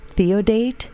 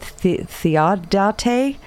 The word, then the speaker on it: Theodate: I looked for a way to pronounce that name. – Commandant Theodate. – I couldn't find it. – Yeah. – And everyone that I listened to that said that name said Theodate.